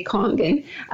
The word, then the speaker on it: Kangen: Well, as far as the name itself, it's pronounced many different ways. It can be pronounced Kangen or Kangen. The machines themselves say Kangen.